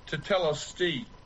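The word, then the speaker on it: tetelestai: But I want to tell you this, that this word that is pronounced tetelesti, it's a Greek word, and it literally is a declaration of victory.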